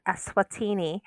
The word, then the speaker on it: Eswatini: And it is, in fact, Eswatini, or Eswatini, if you pronounce it like it's a virtual country.